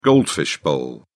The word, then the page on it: goldfish bowl: British English